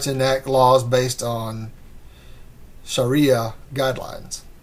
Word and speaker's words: Sharia: The two women were fined by the Shahara High Court. – Sharia. It's Sharia law.